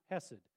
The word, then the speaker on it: hesed: It's pronounced hesed, H-E-S-E-D.